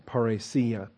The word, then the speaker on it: parrhesia: Parrhesia, if you want to put the accent on the I there where it belongs, parrhesia. P-A-R-R-E-S-I-A, parrhesia.